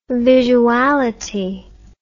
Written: visuality

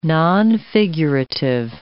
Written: nonfigurative

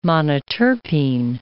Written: monoterpene

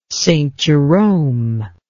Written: St Jerome

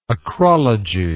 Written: acrologically

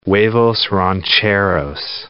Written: huevos rancheros

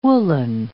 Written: woollen

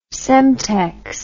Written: Semtex

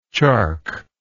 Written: chark

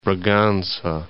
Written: Braganca

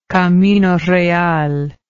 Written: camino real

